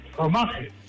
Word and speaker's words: fromage: Fromage.